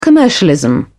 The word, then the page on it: commercialism: British English